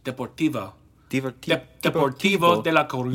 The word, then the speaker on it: Deportivo de la Coruña: Deportivo de la Coruña. – Yes. Excellent pronunciation.